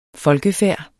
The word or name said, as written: folkefærd